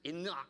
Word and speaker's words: Enoch: And if you look it up in the Hebrew, it's pronounced Enoch. You have to kind of clear your voice when you say Enoch.